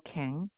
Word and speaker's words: Kang: Chung Seo Kang. – Spell that last name for me. – K-A-N-G.